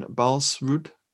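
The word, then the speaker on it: Baalsrud: which is a film about Jan Balsrud, I think it's pronounced, a Norwegian commando. – Yep.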